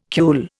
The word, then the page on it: cule: Indian